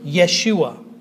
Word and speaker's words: Yeshua: By the way, sometimes you'll see the name Joshua spelled the way it's pronounced in Hebrew, Yeshua, with a Y at the beginning.